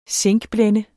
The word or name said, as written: zinkblende